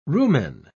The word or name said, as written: rumen